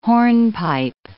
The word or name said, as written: hornpipe